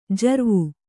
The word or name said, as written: ಜರ್ವು